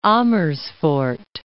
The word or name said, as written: Amersfoort